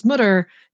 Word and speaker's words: Mütter: Yeah, it's the when you see an umlaut over a letter, just imagine that there's an E next to it. – Thank you. – That's the rule. So Mütter, but you know, you're it's the least important part.